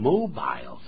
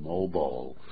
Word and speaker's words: mobile: 这个 mobile phone 也有人念成 mobile phone. – 对，那是英式腔。 – 啊，两个人说法都可以，在美式英语是念成 mobile.